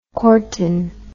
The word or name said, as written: cortin